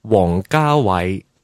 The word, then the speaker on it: Wong Kar-wai: How do you say? – In Cantonese, it's pronounced Wong Kar-wai. – Wong Kar-wai.